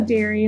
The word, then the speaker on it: Dari: I would say Dari, like legendary. Well, that's for the podcast. I usually say something different. Dari, like gotcha. Dari products.